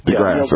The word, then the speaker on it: deGrasse: deGrasse.